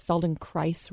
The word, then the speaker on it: Feldenkrais: What we say is Feldenkrais rhymes with rice.